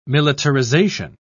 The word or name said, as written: militarization